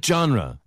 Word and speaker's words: genre: Oh, that's my favorite genre. – 예, 네, 발음 주의하세요. 장르 아니고 G-E-N-R인데 genre 처음 들립니다. Genre.